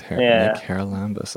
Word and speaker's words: Haralambous: It's easier than it looks. It's Hara Lambus. – It is way easier than it looks. Hara Lambus.